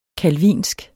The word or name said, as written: calvinsk